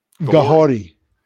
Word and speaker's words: Kahori: Gahori. – Gahori. Gahori. Okay, it's Mohawk. So the Mohawk language, they pronounce their K's like G's mm-hmm. and their R's like D's. So it's Gahori.